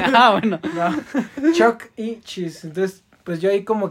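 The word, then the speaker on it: Chuck E. Cheese: ¿Sí, es Chucky and Cheese o Chucky e Cheese? Chucky Cheese. Chuck. Es Chuck y e. Cheese. O sea, I de E.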